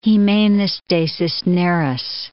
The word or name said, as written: Jimenez de Cisneros